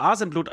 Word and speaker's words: Asenblut: Es ist Tetzel, Halbgottschmied Tetzel, Sänger von Asenblut oder wird Asenblut, ich glaube Asenblut wird es ausgesprochen.